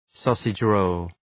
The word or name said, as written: sausage roll